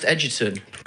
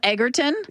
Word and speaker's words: Egerton: Hi, I'm Taron Egerton, and a few people know that I actually... – have a surname that's pronounced Edgerton. – Are you kidding me? Are you kidding me? – I couldn't resist. – So that's an interview calling him Egerton.